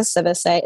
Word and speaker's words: Sivixay: I spell it weird, but if you look up Monica Sivise, and that's how you say my last name, because I'm so sick and tired of people pronouncing my last name incorrectly.